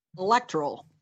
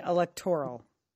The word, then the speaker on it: electoral: I would say electoral. – Mm-hmm. Okay, for the record, the accent is on the second syllable, not the third. Oh. So, you did that correctly. It's electoral, not electoral.